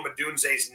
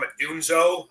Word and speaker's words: Odunze: Smith and Scott Van Pelt, who are mispronouncing Rome Odunze's name. One guy called him a Dunzo.